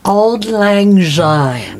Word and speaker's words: Auld Lang Syne: Auld Lang Syne. – I thought it was old anxiety. – Okay. It's not old anxiety or old lasagna, it looks like AULD, like old. Lang, L-A-N-G, which is Greek for New Year's Eve.